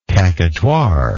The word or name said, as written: caquetoire